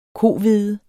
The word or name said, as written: kohvede